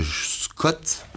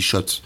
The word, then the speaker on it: Schott: Vous prononcez sûrement euh, Scott, mais en fait on dit Shot.